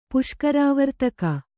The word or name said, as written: ಪುಷ್ಕರಾವರ್ತಕ